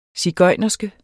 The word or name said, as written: sigøjnerske